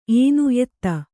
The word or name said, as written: ಏನು ಎತ್ತ